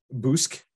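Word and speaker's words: Bossk: So we got Bosk. Bosk is okay. – Sorry, you're pronouncing it wrong. – Boosk. The Boosk.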